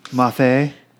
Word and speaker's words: Moffat: Alex Moffat. – Oh, yeah. – Did I pronounce that right? – Yeah. – Okay. – Moffat. – No, he's not French.